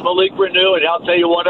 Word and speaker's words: Reneau: Malik Renew or Renault? – Well, we all think it was Renault before we found out that it was Renew.